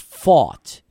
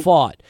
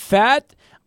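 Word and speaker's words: Pfaadt: Brandon, his last name is spelled PFADT. It's pronounced Fought. It's Brandon Fought. But I've heard Fat. I've heard PFAT. I've heard every possible way to pronounce his name.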